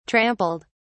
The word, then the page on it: trampled: British English